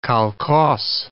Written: kolkhoz

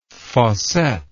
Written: fossette

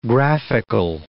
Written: graphical

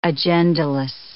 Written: agendaless

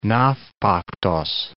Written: Navpaktos

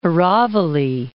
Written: Aravalli Range

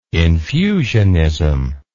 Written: infusionism

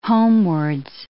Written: homewards

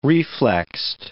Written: reflexed

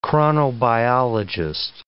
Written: chronobiologist

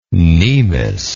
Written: cnemis

